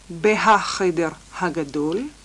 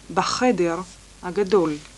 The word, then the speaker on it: בחדר הגדול: אנחנו מוסיפים בית לפני החדר ולא מבטאים את הה. לא אומרים בהחדר הגדול, אומרים בחדר הגדול.